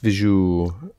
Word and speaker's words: вижу: Я сейчас вижу. Спасибо. – Mm-hmm. Good. Only вижу on the first syllable.